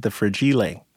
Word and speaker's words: Fragile: So I pronounce it The Fragile.